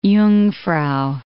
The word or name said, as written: Jungfrau